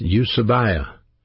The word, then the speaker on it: eusebeia: That word godliness is a Greek word pronounced eusebia. Eusebia, E-U-S-E-B-E-I-A.